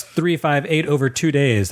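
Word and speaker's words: 358/2 Days: Kingdom Hearts 358 over 2 days. That is how it's pronounced.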